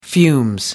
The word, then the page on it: fumes: American English